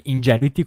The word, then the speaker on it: Ingenuity: Quindi, se vogliamo, è stato un rispescone, e si chiama, come dicono gli americani, Ingenuity, che si legge Ingenuity,